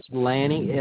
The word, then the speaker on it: Lanny: Yes, Lanny, L-A-N-N-Y. And my last name is Littleton. – Okay, spell it for him. – L-I-T-T-L-E-T-O-N, Littleton.